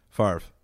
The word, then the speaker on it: Favre: I actually don't know how to pronounce his last name. Brett Favre? – Have you ever seen something about Mary? Favre. – Favre. Really? – Yeah. – How big are his hands? – It's F-A-V-R-E. – What? – It's F-A-V-R-E.